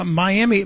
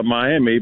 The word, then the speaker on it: Miami: It's spelled like everybody down in in Florida call it Miami, but here in Oklahoma, we pronounce it as Miami. That's the name of the tribe the city was named for.